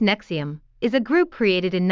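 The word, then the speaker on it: NXIVM: Chibn, pronunciado Nexium,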